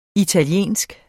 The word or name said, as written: italienske